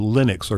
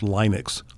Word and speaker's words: Linux: There is a third alternative that most people may be unaware of, and that is called the Linux, or sometimes it's pronounced Linux operating system. – L-I-N-U-X.